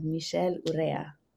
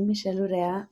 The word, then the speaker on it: Michelle Urrea: Thank you so much for saying my name, how it should be said, Michelle Urrea. That was wonderful. – You're welcome. – So yeah, that's me, Michelle Urrea.